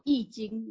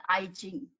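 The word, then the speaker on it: Yi Jing (易经): It's actually called Yi Jing, not I Jing.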